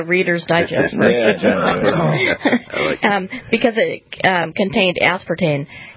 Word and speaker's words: aspartame: And that because Diet Dr. Pepper had aspartame, am I saying that right? – Aspartame. – Aspartame. I knew that was wrong.